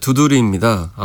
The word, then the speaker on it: 두드리: 매해 도서전은 주제를 정해서 하고 있는데 올해 도서전의 주제가 인간선언, 호모 두드리라고 읽나요? – 네, 그렇습니다. 네. 두드리입니다.